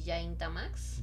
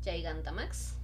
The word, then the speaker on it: Gigantamax: el hecho de que las formas Dynamax y Yaintamax. – Yaiganta. – Gigantamax.